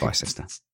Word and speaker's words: Bicester: Bicester. It's pronounced Bicester.